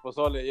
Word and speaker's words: Pozole: Did I say it right? Pozole. – Pozole. Yeah.